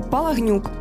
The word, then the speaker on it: Палагнюк: В пам'ять про українське походження залишив собі колишнє прізвище Палагнюк. Саме так в оригіналі вимовляється прізвище письменника.